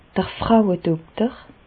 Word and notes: d'r Frauedokter: Bas Rhin; Reichshoffen

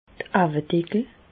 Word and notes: d'r Auwedeckel: Bas-Rhin; Reichshoffen

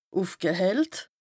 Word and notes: ùfgehellt: Bas-Rhin; Strasbourg

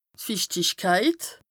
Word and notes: d' Fichtichkeit: Bas-Rhin; Strasbourg

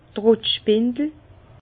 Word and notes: d' Trottspindel: Bas-Rhin; Herrlisheim

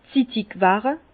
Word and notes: 's Zittigwerre: Bas-Rhin; Herrlisheim

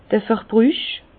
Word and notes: d'r Verbrüch: Alsatian; Bas-Rhin; Herrlisheim

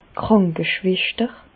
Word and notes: d' Krànkeschweschter: Bas Rhin; Reichshoffen

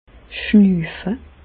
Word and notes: schnüüfe: Bas-Rhin; Reichshoffen

